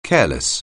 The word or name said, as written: careless